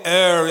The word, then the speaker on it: err: If you're a perfectionist in English, you will tell me that I have mispronounced the word E-R-R, and it should be err. You can have your pronunciation. I will continue to pronounce it the way I do, which is err. All right, like error.